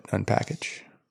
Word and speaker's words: unpkg: But yeah, I mean, you would pronounce it Unpackage, right? – Yes.